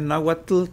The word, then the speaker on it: náhuatl: Fíjate que, bueno, ahí hay que aclarar de que es náhuatl con TL, Porque esas son las variantes mexicanas. Ah. Es el, el náhuatl. Se pronuncia así, al final, que la TL.